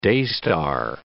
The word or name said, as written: daystar